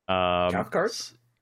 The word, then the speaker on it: Cathcart: We have Anna Cathcart. She's been on a lot of stuff. – Cathcart? – Yeah, Cathcart.